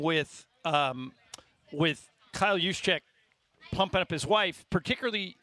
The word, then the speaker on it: Juszczyk: You're close. Juszczyk.